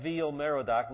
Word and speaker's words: Evil-Merodach: And then, after Nebuchadnezzar's death, Nebuchadnezzar's successor, Evil-Merodach, and we'll read about him in just a minute, Evil-Merodach, not evil Marduk, not evil, it's Evil. It looks like our word evil, but it's really not. I want to pronounce it properly so you don't get mixed up here.